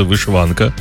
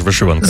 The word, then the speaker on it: вишиванка: Я чув завжди вишиванка, а ти кажеш вишиванка. – За новим правописом наголос подвійний. І вишиванка, і вишиванка.